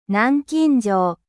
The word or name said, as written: cadenas